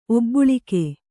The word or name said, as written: ಒಬ್ಬುಳಿಕ್ಕು